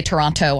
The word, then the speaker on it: Toronto: You know, like Toronto, you don't say the second T.